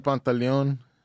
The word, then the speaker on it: Pantaleon: Yeah, Marvin Pantaleon.